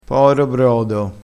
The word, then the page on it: poro brodo: dialettale